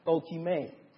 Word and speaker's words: dokimai: 老练这个词希腊文它怎么读呢？叫做 dokimai，希腊文这个词 dokimai 这个词，那这个词是讲什么呢？